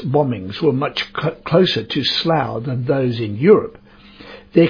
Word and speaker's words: Slough: The name of that town is spelt S-L-O-U-G-H and pronounced Slough.